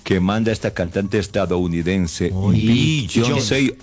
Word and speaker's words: Beyoncé: Beyoncé. – ¿A Beyoncé? – Beyoncé. – Sí, Beyoncé. – Se pronuncia. – Sí, sí, sí, Beyoncé.